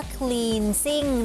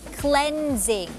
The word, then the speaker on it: cleansing: ไม่ได้อ่านออกเสียงว่า cleansing นะคะแต่ว่าอ่านออกเสียงว่า cleansing ค